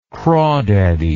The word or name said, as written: crawdaddy